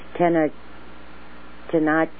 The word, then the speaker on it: Tenochtitlan: You've got this thing, it's called, you'd have to pronounce it for me, Tenochtitlan.